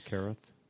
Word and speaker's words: Carruth: Shane Carruth. – Yeah, Shane Carruth. And Shane Carruth, I think.